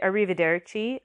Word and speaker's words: arrivederci: This one's tricky to pronounce with the rolling your R's, but everybody knows it. Arrivederci, arrivederci. That can be, I always find that hard with the two r.